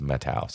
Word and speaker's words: Methow: And recently I was camping there and I finally asked somebody, is it Methow or Methow? And they said it's Methow. So I've been pronouncing it wrong for just about all of my life.